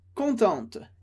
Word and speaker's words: content: E a pronúncia ficaria content. Content. Sem pronunciar o T. Então, vamos lá. Contente masculino, content. No feminino, contente. Eu não pronuncio o E do final, mas pronuncio o T que vem antes.